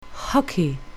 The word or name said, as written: hockey